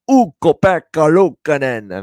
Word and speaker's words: Ukko-Pekka Luukkonen: He put it with that perfect Swedish accent on how you pronounce that name, Ukko-Pekka Luukkonen.